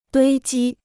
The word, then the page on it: 堆积: duī jī